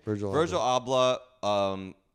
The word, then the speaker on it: Virgil Abloh: Virgil Abloh collaborated with Nike and on these insane Jordan ones.